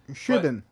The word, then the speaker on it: Siobhan: Like, I had a customer when I was a barista who her name is spelt like C O Ban. S-I-O-B-H-A-N. – Shivan. – Siobhan. – Siobhan? – Oh, yeah. – I've been saying that pronounced shit the wrong way.